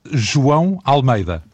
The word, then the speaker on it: João Almeida: Juan Almeida, ça s'écrit J-O-A-O Almeida. Et on prononce João Almeida.